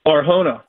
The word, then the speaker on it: Arjona: Do I pronounce your name Arjona? – Arjona. – Arjona. Okay, because somebody corrected me and said it was a hard J. So it's spelled with a J, A-R-J-O-N-A.